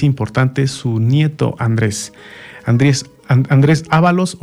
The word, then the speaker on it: Ábalos: Andrés Ábalos o Andrés Ábalos. En la edición que yo tengo es Ábalos, con acento en la segunda.